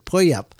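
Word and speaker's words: Puyallup: Somebody said that's how you pronounce it, Puyap.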